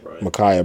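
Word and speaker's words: Ma'Khia: Michaela Bryant. – Micaiah Bryant. – Bro. – I'm fucked up, bro. – It's easy to do. – I apologize for mispronouncing her name.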